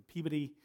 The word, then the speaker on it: Peabody: My wife grew up in Peabody.